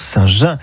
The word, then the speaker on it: Saint-Jean: Ça s'écrit Saint-Jean, mais ça se prononce Saint-Jean. – Et oui, à la Provençale, bien sûr.